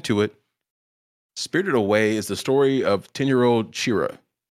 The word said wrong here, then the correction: Chihiro: How do I say her name? – Chihiro, excuse me, guys.